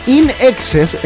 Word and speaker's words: INXS: Mira, In Excess, pronunciado es In Excess, en inglés en exceso, fue una banda australiana de rock formada por los hermanos Farris en 1977.